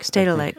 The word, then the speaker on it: Stedelijk: Stedelijk? – Stedelijk, yeah, museum in Amsterdam.